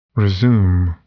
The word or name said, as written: resume